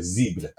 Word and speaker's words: Zebra: It's the Italian version of Zebra, so Zebra. – You think I won't do that? That's hard. With the accent on the E.